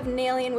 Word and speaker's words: Naelyan: It is spelled N-A-E-L-Y-A-N, although it's pronounced N-A-I-L-Y-O-N, so like Nalian, but it's spelled like Yan, Nalian, but you don't say it that way, you say it Nalian.